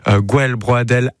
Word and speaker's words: Gwelbroadel: Gwelbroadel, Arbrezonek, je l'ai bien prononcé ? – Parfaitement.